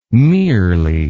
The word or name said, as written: merely